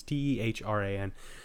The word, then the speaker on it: Tehran: Tehran. Or I think I'm probably butchering that pronunciation. It's Tehran.